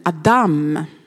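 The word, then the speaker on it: Adam (אדם): Eller Adam uttalas det.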